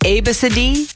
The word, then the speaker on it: Abcde: A-B-C-D-E, and it's pronounced Abacidi.